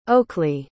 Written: Oakley

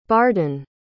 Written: bardan

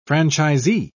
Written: franchisee

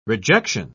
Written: rejection

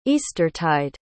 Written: eastertide

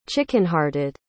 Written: chickenhearted

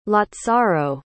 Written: lazzaro

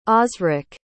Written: osrick

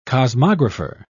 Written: cosmographer

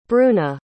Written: bruna

